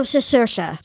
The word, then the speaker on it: Cicerchia: Oh, Cicerchia.